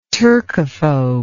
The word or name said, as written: turkophobe